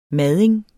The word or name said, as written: MADING